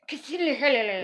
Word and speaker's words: Cthulhu: Is that how you pronounce it? – Cthulhu? – I never knew what that word was. – That's how I say it. – Cthulhu.